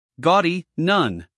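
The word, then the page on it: gaudy: uk ˈɡɔːdi; en-us ˈɡɔːdi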